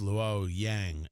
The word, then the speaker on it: Luoyang: I believe it's pronounced Luoyang, L-U-O-Y-A-N-G.